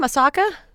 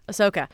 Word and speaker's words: Ahsoka: Yeah, I think, how do you say her name? Ahsoka. There we go. Ahsoka.